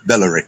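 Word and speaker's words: Bolloré: B-E-L-O-R-E, with an accent on the E, Bellore.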